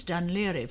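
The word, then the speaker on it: Dún Laoghaire: Now it's spelt Dunlaoghaire. – Yes, and pronounced quite differently.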